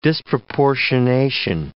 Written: disproportionation

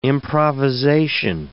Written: improvisation

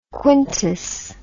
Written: quintus

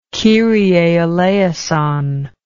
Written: kyrie eleison

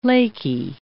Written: laky

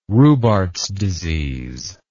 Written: Rubarth's disease

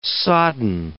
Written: sodden

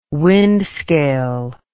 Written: wind scale

